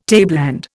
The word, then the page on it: tableland: Indian